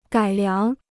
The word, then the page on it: 改良: gǎi liáng